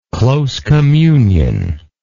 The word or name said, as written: close communion